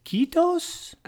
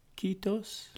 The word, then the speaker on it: Kiitos: And so when Keith was over there in Finland, did somebody like say his name is Kitos? – K-O. – Kiitos.